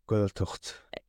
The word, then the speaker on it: Gaeltacht: You grew up in a Gaeltacht. – Gaeltacht.